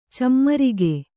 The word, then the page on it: ಚಮ್ಮರಿಗೆ: cammarige